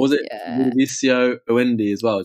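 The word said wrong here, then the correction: Ludovico: Oh, yes. Ludovicio. – Yeah, I've definitely said that wrong.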